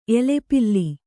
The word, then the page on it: ಎಲೆಪಿಲ್ಲಿ: kannada